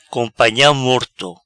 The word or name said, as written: Companha Morto